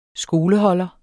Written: skoleholder

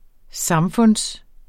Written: samfund

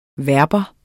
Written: verbum

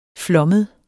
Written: flommet

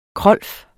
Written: krolf

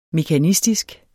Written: mekanistisk

